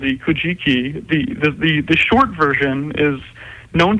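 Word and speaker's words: Kojiki: The Kujiki? texts. – Hey, got it again. Excellent. – It was luck. It looks like it sounds.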